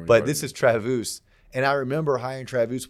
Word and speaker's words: Travus: His name is Travus. Friends call him Travis, but it's spelled T-R-A-V-U-S. His dad's German, whatever. I call him Travus because I think it sounds cooler than Travis.